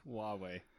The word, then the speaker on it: Huawei: Hawaii. Am I pronouncing that right? – No, Huawei. – Huawei, that is right.